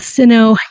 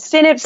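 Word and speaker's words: Sinnott: And Sino, how do you say it? Sino Boxers? – Sinit Boxers, yeah. – Not C. That's an S.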